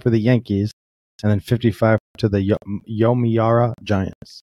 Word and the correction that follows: Yomiuri: Well, considering it ends in an I and not an A. – Yomiya. – It's Yomiri. – No, it's not. Definitely not that. – Yomiuri.